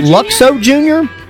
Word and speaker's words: Luxo Jr.: Is that Luxo Jr.? – In 1986, that's the desk lamp.